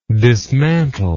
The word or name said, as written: dismantler